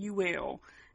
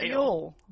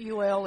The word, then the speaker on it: Youell: I've heard it pronounced Yule and U-L. It's spelled Y-O-U-E-L-L. – It's Yule. – Yule. That's how people around here would pronounce it, but I have heard some people pronounce it as U-L. – It's not U-L, it's Yule.